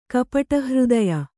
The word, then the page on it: ಕಪಟಹೃದಯೆ: kapaṭa hřdaye